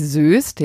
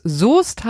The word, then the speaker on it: Soest: Ähm, und habe es einfach diese Stadt äh, fürchterlich falsch ausgesprochen, nämlich Söst, ja, aber es muss Soest heißen.